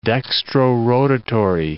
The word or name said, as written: dextrorotatory